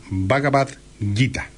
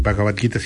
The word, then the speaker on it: Bhagavad Gita: indio de la India que se llama Bhagavad Gita. Se escribe B larga H A G A B corta A D Bhagavad y Gita o Gita sería mejor G I T A Bhagavad Gita.